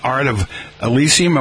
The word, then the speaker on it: Art of Elysium: Art of Elysium.